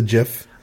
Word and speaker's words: GIF: G-I-F. – But how do you pronounce that? – Apparently it's GIF. According to the creators of the GIF, it is correctly pronounced GIF. Of course, because it stands for Graphics Interchange Format.